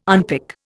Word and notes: unpick: Indian

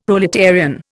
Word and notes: proletarian: Indian

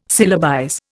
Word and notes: syllabize: Indian

